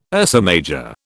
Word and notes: Ursa Major: British English

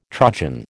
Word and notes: trudgen: American English

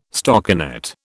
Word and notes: stockinet: British English

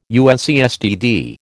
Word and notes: UNCSTD: American English

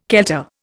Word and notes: kelter: Indian

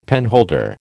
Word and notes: penholder: American English

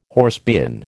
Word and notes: horsebean: American English

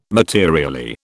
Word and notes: materially: British English

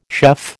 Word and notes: chef: American English

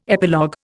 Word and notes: epilogue: Indian

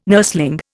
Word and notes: nursling: Indian

